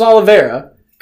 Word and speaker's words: Oliveira: Charles Oliveira.